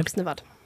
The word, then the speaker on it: Oksnevad: Eh, var det mulig Oksnevad, eller var det mulig Oksnevold? Eh, mulig Oksnevad.